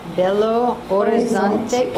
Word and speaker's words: Belo Horizonte: And how do you say the name of this place? Belo Horizonte. – Belo Horizonte. Or you don't say the H.